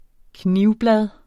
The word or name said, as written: knivblad